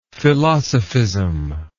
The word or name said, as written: philosophism